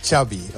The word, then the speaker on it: Xavi: Xavi. – Ya, okay. Xavi like chocolate.